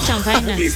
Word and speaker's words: chanfaina: Ni lo sabes pronunciar. – A ver, ¿cómo es? – Chanfaina. – Muy bien, eso.